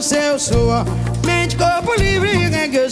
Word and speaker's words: reggae: Tudo isso graças ao ritmo que, nos anos 1970, conquistou o coração dos maranhenses, o reggae, ou como dizemos na pronúncia brasileirada, reggae.